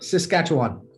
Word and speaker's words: Saskatchewan: Saskatchewan. – Saskatchewan.